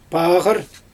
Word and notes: paaGyr: Xyzyl

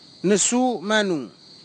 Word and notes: nisu manug: Kwamera

